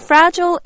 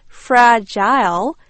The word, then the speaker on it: fragile: You know, fragile is an adjective which means easily broken or damaged, very delicate. It's not pronounced fragile. Instead, it's pronounced fragile.